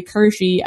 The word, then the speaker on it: Kirschy: I was like, oh, no, like, they're saying it correctly. Like, my last name is actually Kirschy. I just go by Kirsch because it's simpler.